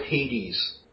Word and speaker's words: Hades: Do you spell it like the Greek form, or is it like H-A-T-I-E-S? No, it's like the Greek Lord of the Underworld. – Yes. I would go with Hades, like hate.